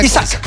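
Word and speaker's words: Isak: Isaac. ¿Se pronuncia Isaac? – Isaac.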